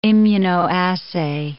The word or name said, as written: immunoassay